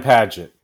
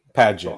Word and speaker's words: Paget: Paget? Brian Paget, but Paget. Oh, yeah. – Brian Paget. – But it's like Page with a T.